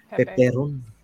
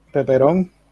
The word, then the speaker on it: Peperon: How do you pronounce it? I just said it in Spanish. – Peperon. – Peperon. It's like pepperoni.